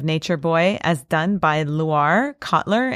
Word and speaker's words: Loire: This is a Nature Boy, as realized and performed by Loire Kotler.